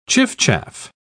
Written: chiffchaff

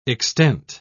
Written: extent

